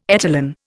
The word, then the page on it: etalon: Indian